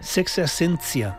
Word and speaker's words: Sexta Essència: Sexta Essència. És així com m'ha dit el, com m'han dit que es pronuncia. – Sexta Essència.